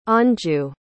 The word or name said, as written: Anju